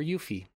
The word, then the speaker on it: Yuffie: And 66% of people said, give me Yuffie. Or Yuffie, as it's pronounced, actually.